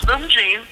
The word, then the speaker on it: Moomjean: No, it's Moomjean. It's Armenian.